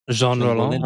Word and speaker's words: Jean Rollin: Jean Rollin.